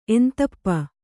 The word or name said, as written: ಎಂತಪ್ಪ